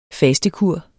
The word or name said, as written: fastekur